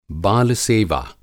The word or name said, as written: बाल-सेवा